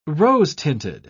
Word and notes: rose-tinted: British English

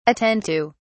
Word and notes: attend to: British English

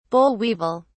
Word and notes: boll weevil: British English

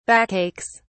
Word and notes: backaches: British English

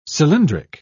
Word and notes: cylindric: English; British English

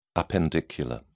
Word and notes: appendicular: British English

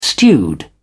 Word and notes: stewed: British English

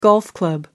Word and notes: golf-club: American English

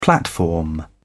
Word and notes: platform: British English

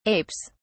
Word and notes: apes: British English

